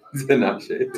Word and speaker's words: Tinashe: Tinashe.